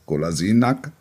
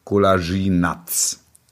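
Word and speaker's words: Kolašinac: die Aussprache des Nachnamens des Spielers Golasinak, er ist jetzt nicht mehr Nack, sondern Natsch. Und jetzt, Achtung, dringende Mitteilung an alle Redaktionen, Spieler heißt jetzt Golasinatz.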